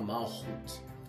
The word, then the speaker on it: Malkut: Se escreve Malkut, mas se fala Malhut.